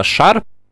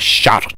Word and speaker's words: шар: Последнее — это когда звук «ш» произносится мягко, он похож на «ща». Это, кстати, частая ошибка у многих дикторов, которые хотят произносить звук четче. У них вместо «шар» произносится «щар».